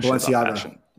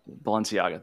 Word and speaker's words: Balenciaga: So what do you think was really going on with the Balenciaga? How do you fucking pronounce it, man? I don't know. – Balenciaga. – Balenciaga, thank you.